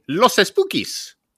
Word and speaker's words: Los Espookies: You had Black Lady Sketch Show, you had Los Espookies, which was canceled, and you had... – Come on, say it properly. – Los Espookies. – There you go.